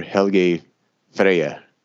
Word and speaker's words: Helgi Freyr: So, and that's just under Helgi Freyr. I didn't pronounce Helgi Freyr Thomasson.